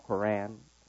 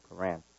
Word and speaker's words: Quran: In our holy book, the Quran, it's called Quran. By most Westerners, they pronounce it Quran. Quran, K-O-R-A-N, Quran. Pronounced correctly by Muslims, Quran.